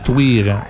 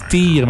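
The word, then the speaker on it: Týr: Tuir, ça se prononce Tuir. Le monde pense que ça se prononce Tyr, mais ça se prononce Tuir.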